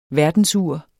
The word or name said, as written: verdensur